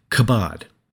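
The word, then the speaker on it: kabod (כבוד): In the Old Testament, the word for glory is pronounced something like kabod.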